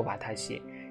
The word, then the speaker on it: 私: 因为大家可能稍微了解一点的人，可能知道这个我，它写作汉字私，私密的私啊，它念作瓦塔西。这个阿塔西呢，它也写作私。这个瓦塔克西呢，它也写作斯，它们三个有什么区别呢？啊，瓦塔西、阿塔西和瓦塔克西都写作斯，但是它有什么区别呢？